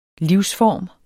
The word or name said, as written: livsform